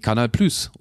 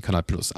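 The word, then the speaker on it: Canal+: Und Premiere war aber damals eine Art Gemeinschaftsaktion von eben der Kirchgruppe, aber auch von der Bertelsmann AG, also RTL, und von, jetzt muss ich es richtig aussprechen, Canal Plus, oder? Sagt man. Mhm. Ja, ich hätte es auch eindeutschen können, Canal Plus.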